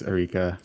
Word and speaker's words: Arika: Arika? Is that how you pronounce it? – Yeah, I think it's Erika.